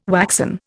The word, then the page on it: waxen: Indian